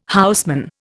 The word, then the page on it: houseman: Indian